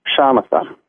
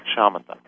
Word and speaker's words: Shamatha: How is the vipassana different than the, and how do you say it? – Shamatha. – Shamatha.